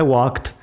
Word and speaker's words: walked: Así que I walked, she ate, they drove. Walk es un verbo regular, por eso tenemos la ed. En este caso se pronuncia como si fuera te.